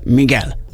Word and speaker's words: Miguel: Igen, egy kedves hallgató megírta, a Miguel kiejtése Miguel. Köszönjük szépen.